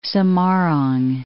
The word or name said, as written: Semarang